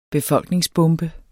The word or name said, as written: befolkningsbombe